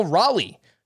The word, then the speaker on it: Raleigh: And it's pronounced like Raleigh Fingers. So in case anybody wanted to know. – It's spelled like the capital of North Carolina, though.